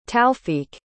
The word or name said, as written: taufeeq